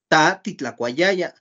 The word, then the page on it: ta titlakuayaya: Náhuatl